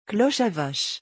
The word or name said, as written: cloche a vache